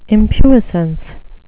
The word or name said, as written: impuissance